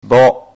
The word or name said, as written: bo